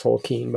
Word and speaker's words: Tolkien: Yeah, volume 3, where they have Tolkien, and it's pronounced Tolkien, by the way.